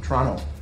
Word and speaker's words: Toronto: Toronto. Canadians don't pronounce the T.